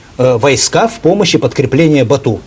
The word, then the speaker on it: Бату: Бату или Бату, правильно? – Бату, средневековое монгольское произношение, бат, современное монгольское, означает сильный.